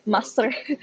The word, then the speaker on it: Maastricht: I don't know if you're saying it right. I'm still trying to... Maastricht? Maastricht, they pronounce Maastricht.